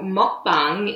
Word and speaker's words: mukbang: And so apparently this was popularized in South Korea, and I may have the pronunciation wrong. You can correct me. It's apparently called mukbang.